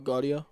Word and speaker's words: Gaudio: How do you pronounce that one's name? – Starts with a G. – Oh, Gaudio. Yeah, Gaudio. Dino Gaudio.